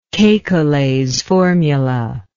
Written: kekules formula

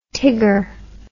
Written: Tigger

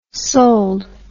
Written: soled

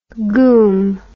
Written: goum

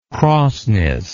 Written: crossness

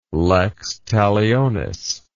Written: lex talionis

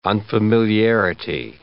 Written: unfamiliarity

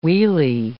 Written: wheelie

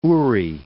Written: Uri

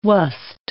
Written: wast

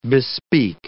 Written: bespeak

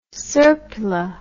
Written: serpula

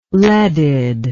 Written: leaded

